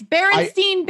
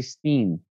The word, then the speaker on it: Berenstain: It's Berenstain Bears. – And like, people say it. Even today, it's spelled Stain, but you talk to people, they say Steen.